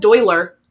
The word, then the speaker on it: Doyler: In contrast, Doyler, which rhymes with boiler and that's weird, is outspoken, rebellious, brave, and affectionate.